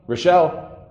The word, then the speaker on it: Rochelle: How do you pronounce it? – Rochelle. – The La is just like the? – Yeah.